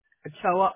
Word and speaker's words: Ochoa: S-H-A, V as in Victor, O-N-N-E. – Your last name? – Ochoa, O-C-H-O-A.